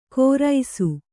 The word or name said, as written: ಕೋರೈಸು